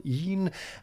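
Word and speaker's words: e'en: Even, as so often, is pronounced with one syllable, een, and antique, as we would say, here is stressed on the first syllable, antique, in order for the line to scan.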